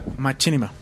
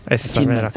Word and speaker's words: Machinima: Machinima. – Machinima.